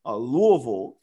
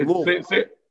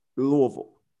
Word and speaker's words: Louisville: So, you said Louisville. I mean, they don't call it, you know, they don't call it. – No. – It's Louisville. – Say it right again. Say it right. – Louisville. – That's right, my brother.